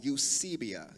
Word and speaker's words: Eusebia: In this particular wording of the word godliness in the Greek, there's a word called Eusebia. Eusebia. Someone say Eusebia. Say it again. Say Eusebia. Trying to teach y'all a new word. Eusebia. It is how the word is pronounced.